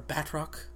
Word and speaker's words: Batroc: But or Batroc. That's how they pronounce it.